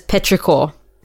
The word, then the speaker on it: petrichor: I've pronounced it as petrichor. – Yeah, that's it. Petrichor.